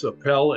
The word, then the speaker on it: Apel: Apel, A-P-E-L. Rhymes with swell or go to hell. Yeah. Drop down a well.